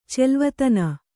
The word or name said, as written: ಚೆಲ್ವತನ